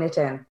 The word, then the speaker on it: Nitin: Welcome, Nitin.